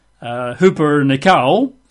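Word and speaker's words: Huper nikao (ὑπερνικάω): Huper nikao. So if you're taking notes, you'd spell that in English letters. H-U-P-E-R. Huper. H-U-P-E-R. N-I-K-A-O.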